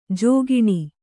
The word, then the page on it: ಜಿಕ್ಕಿಣಿ: jikkiṇi